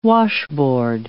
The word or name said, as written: washboard